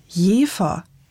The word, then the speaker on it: Jever: Die Stadt Jever, so sagt es zumindest Wikipedia, wird ausgesprochen mit einem stimmlosen F wie Cuxhaven, Bremerhaven, obwohl mit V geschrieben. Und bei dem Bier, da wählt man eher das stimmhafte W.